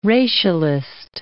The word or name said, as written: racialist